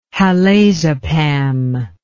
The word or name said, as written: halazepam